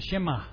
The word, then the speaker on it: Shema: It's the Shema. Pronounced Shema, Shema.